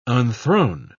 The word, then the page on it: unthrone: British English